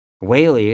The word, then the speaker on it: Whaley: Whaley is pronounced Whaley, like Bailey. Only Whaley.